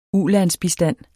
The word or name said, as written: ulandsbistand